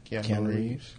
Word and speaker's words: Keanu Reeves: Keanu Reeves.